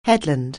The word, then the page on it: headland: anglais